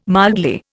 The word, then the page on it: mildly: Indian